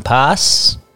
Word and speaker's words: pas: Did pas mean pass? You slip up there? – It might. I don't know. – Hmm. – No, usually that S is silent in French when you say that.